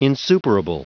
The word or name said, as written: insuperable